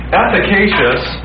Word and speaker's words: efficacious: Now, when you look at the whole sacrifice of Jesus then, we often call the sacrifice of Jesus efficacious.